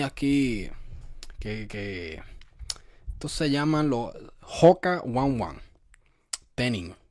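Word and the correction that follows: Tennine: Hoka One Tenin, yo no, Ten-Nine. Oh, se pronuncia Ten-Nine.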